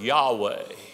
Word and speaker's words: Yahweh: And you take the word Adonai and the vowels of the word Adonai and put them in there, because Adonai means Lord. And you pronounce the word Yahweh.